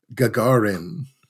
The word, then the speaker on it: Gagarin: It's good for ages 13 and up, and it's a film called Gagarin.